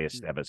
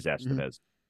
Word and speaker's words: Estevez: Maybe adding a Carlos Estevez, or I know I always put the emphasis on the wrong syllable. I want to say Estevez because I think it's just kind of a cooler name, but it is Estevez.